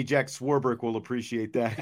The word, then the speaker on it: Swarbrick: yes, Swarbrick. There's no there's no eight or there's no ch in Swarbrick.